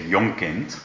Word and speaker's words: Jonkind: Surname is, well, in Dutch we would say Jonkind. – Jonk. – But my children use four different sort of pronunciations of it in English. Jonkind, Jonkind, Jonkind, Jonkind, and Jonkind.